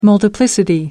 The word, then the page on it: multiplicity: American English